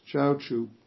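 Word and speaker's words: Zhaozhou: Actually, it's Chaochu, but I can't pronounce the Chinese well, so I'll say it in the Japanese pronunciation of his name.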